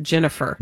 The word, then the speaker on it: Ginnifer: I think we pronounce it Jennifer. – Jennifer Goodwin. – G-I-N-N-I-F-E-R. Goodwin.